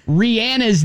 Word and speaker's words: Rihanna: I don't know if you've been saying Rihanna's name wrong this entire time, but she says it. – It's Rihanna.